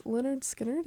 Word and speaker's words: Lynyrd Skynyrd: And then the one before that was Lynyrd Skynyrd. Did I say that right? – Yep.